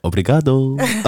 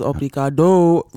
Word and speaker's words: obrigado: All Omar was saying was obrigado, really making sure that he pronounces the O in obrigado, which was quite funny.